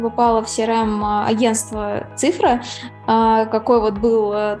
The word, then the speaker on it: CIFRA: Да, это только маленькая ремарочка, у нас ударение на «а», цифра.